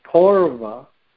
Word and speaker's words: paurava: So paurava comes from the word purva, with a long U, purva meaning earlier or belonging to the past, with a long U. And then you can intensify it, paurava, with a, instead of a long U, an AU.